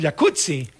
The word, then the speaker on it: jacuzzi: Entonces deberíamos pronunciar el jacuzzi. – Exacto. – Como pizza. De... como rampazzo, sí.